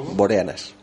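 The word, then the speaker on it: Boreanaz: Nuestro uno de nuestros vampiros favoritos, el David Boreazna... Ah, no sé si lo he dicho bien del todo. Boreanas.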